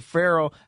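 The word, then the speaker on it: Ferrell: Cleveland Farrell has become that guy. Damn, pronounced it Farrell for the first time.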